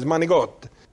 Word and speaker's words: manicotti: It's not manicotti, it's manigot.